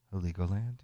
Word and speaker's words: Heligoland: Massive Attack starting off that set from the album Heligoland. I think I said that right. Heligoland, yeah.